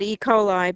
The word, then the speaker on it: E. coli: I'm referring to the E. coli bacteria epidemics. I don't know if I pronounced correctly. – Yeah, that's what we always call it, E. coli, but it's E.